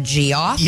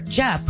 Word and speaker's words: Geoff: Ted Knobs, 83, and brother, it says G-E-O-F-F, but I think it's pronounced Jeff instead of G-O-F. – Yes, that's correct.